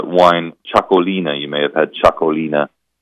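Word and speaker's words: Txakolina: this Chacolina. It is spelled, by the way, Txacolina, Chacolina.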